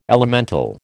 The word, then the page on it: elemental: American English